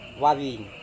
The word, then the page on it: wavin: Serewen